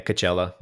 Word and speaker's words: Coachella: but I presume it's pronounced Coachella. – That's usually how people say it. – Yeah, Coachella. Yeah.